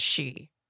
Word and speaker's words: sidhe: And it's called a cat's, it's spelled S-I-D-H-E, but it's pronounced a cat she.